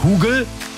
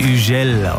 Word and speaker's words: Hugel: Das ist ein französischer DJ, deswegen heißt er nicht Hugel, so wird er nämlich geschrieben. Er wird Hugel ausgesprochen. Schon klingt es viel cooler.